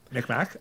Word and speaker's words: Mi'kmaq: Correct me if I'm pronouncing this incorrectly, but Macame, the people out in Nova Scotia. – The Mi'kmaq fishers? – Mi'kmaq. I only heard of that from the Discord.